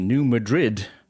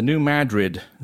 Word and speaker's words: New Madrid: Earlier, I referred to the earthquake zone in the Midwest as the New Madrid zone.